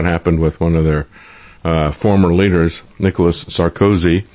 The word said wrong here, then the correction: Sarkozy: is now facing Sarkozy, I think is the way you pronounce that. Emphasis is on the wrong syllable when I said it.